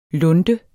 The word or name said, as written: lunde